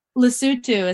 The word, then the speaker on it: Lesotho: Lesotho is how it's pronounced.